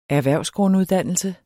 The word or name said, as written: erhvervsgrunduddannelse